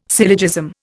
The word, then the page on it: syllogism: Indian